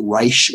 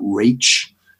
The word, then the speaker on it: Raich: And then that Reich case, but, and it's spelled R-A-I-C-H, but I think it's pronounced Reich and not Rach.